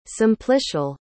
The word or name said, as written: simplicial